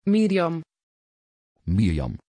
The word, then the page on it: Myriam: Dutch